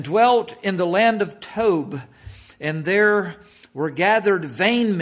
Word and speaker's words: Tob: He got away from them, dwelt in the land of Tob, T-O-B, but pronounced T-O-B-E, Tob, a city north of Gilead at the border of Syria.